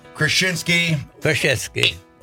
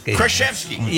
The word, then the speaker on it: Krzyzewski: Krzyzewski. What 100? Did I pronounce it wrong all the time? – Krzyzewski. Yeah, there you go.